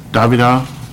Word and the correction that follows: Davila: is that correct? Did I pronounce his last name correct? – Davila, yeah. – Davila, okay.